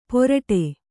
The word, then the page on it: ಪೊರಟೆ: Kannada